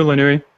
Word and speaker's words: Lanoue: Is it Lanou, Lanoui? – It's Lanou.